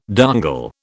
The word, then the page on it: dongle: British English